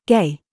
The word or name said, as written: GIG